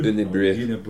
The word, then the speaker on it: Unibroue: From Unibrow. – It's spelled Unibroue. – Right. – And that looks like Unibrow to me. But it's Unibrow, or Unibrew. – Unibrew. – Unibrew, whatever. Unibrew.